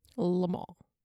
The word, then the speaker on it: Le Mans: Le Mans. – Le Mans. You actually did a great job pronouncing that, I'm not going to lie.